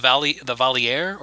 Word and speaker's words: Valier: So, and also the Valier, if we're French, are the queens of the Valar. I guess, Alex, am I pronouncing that correctly? – Perfectly.